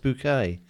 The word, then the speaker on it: Bucket: The main character is a snobbish, lower middle class social climber named Hyacinth Bucket, played by Patricia Routledge, who insists her surname is pronounced Bouquet.